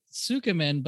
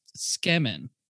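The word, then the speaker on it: tsukemen: So a lot of people pronounce it Tsukamen, but it's actually pronounced Skemen.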